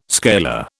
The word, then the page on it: scalar: British English